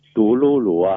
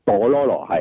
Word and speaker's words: 杜魯魯: 個官位咧就係火炮營嘅營長啊嘛，係啦，咁又同佢一樣啦，佢又跟住再係啦，跟住正仲有大隻佬啊李家角色咧，咁就其實佢同似嘅角色咧就係杜魯魯啊，唔係朵羅羅，係讀錯咗。